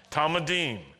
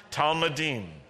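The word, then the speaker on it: Talmidim: Now, how do you say disciples, plural, in Hebrew? Talmudim. Good, tell that to the person next to you if they didn't say it. Talmudim.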